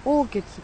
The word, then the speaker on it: 横穴: 轟渓谷三号王穴、横穴と書いて王穴って読むんですね。古墳時代末から奈良時代にかけて横穴群が作られるようになります。農民の墓だっていうことですね。 3 基の横穴が調査され、現在は三号王穴